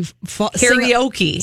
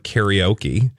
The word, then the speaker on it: karaoke: And also, especially for people who do that, what you don't realize is that the word karaoke, the okay part, is actually English. So it's a Japanization of an English word. So they're not pronouncing it right. So if it was supposed to be karaoke, it would be karaoke or karaoke because it's orca for orchestra, right? – That was so meta.